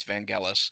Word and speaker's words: Vangelis: I think that's the pronunciation. I think I've heard him say that. It's Vangelis.